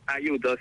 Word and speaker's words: Ayuto: Ayuto, sí, es fácil, es italiano el apellido, así que Sí, está bien pronunciado.